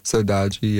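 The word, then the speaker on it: saudade: Okay, you were given Phases' theme of Sodage. Am I getting better at the pronunciation, by the way? – Sodaji, yeah. – Sodaji. He's not happy. It's Sodaji.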